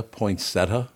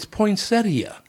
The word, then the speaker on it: poinsettia: Do people really pronounce poinsettia, poinsetta? – Yes, yeah, they do. Poinsetta. – That's how I heard it for the first 20 years of my life. – It's poinsettia.